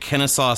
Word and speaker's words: Kennesaw: And you, uh, you, uh, you, you, uh, you teach at Kennesaw State University.